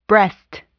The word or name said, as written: breast